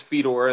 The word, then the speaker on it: Fedor: Chris Fedor, and the last name is spelled F-E-D-O-R.